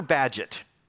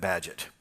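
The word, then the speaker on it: Bagehot: And Walter Badgett, and by the way, you won't get his name by thinking about it. It's B-A-G-E-H-O-T. It takes the English to pronounce that Badgett.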